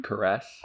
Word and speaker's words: cuirass: Cuirass.